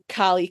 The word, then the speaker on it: Karlee: But if you want to reach out to me, I am Carly Karma. So that is K-A-R-L-E-E. And then Karma, as you would spell it normally. You can find me on AO3 on Twitter. And my Discord name is also Carly Karma.